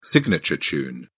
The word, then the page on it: signature tune: British English